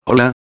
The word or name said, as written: hola